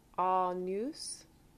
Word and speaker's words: Agnus: Pia Jesu Domine Dona Eis Requiem Agnus. Make sure you don't say the G.